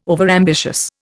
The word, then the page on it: overambitious: Indian